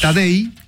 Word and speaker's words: Tadej: Tadej. Como Tadeo, pero Tadej Pogačar. Pogačar se escribe.